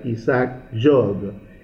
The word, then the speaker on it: Isaac Jogues: El otro se llama Isaac Jogues pero se pronuncia Isaac Job.